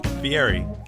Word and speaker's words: Fieri: Guy Fieri? – Fieri.